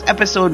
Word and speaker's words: Epixode: His name is Episode, but it's spelled with an X instead of an S. So it's EPIXODE, Episode. Give him a shout out. It's Episode Music on the Facebook.